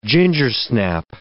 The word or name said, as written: gingersnap